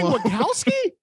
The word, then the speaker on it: Wachowski: So like, you've been saying Wachowski? – It's Wachowski. I don't know why I say Wachowski.